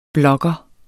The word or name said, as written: blogger